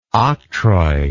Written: octroi

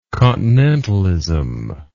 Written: continentalism

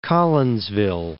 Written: Collinsville

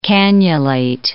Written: cannulate

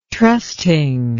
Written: trusting

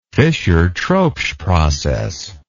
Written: Fischer-Tropsch process

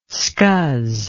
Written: scuzz

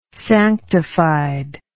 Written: sanctified

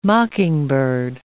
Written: mockingbird

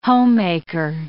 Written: homemaker

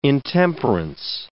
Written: intemperance

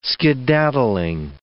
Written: skedaddling

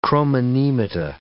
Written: chromonemata

